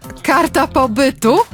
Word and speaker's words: karta pobytu: Tell me, okay, so if I'm pronouncing this correctly, Kata Pobitu? Pobitu? Kata Pobitu? Is that the correct pronunciation? – Karta pobytu.